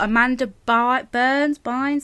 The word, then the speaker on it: Amanda Bynes: You've got Zac Efron, you've got Amanda Bynes, is that how you say it? – Yeah.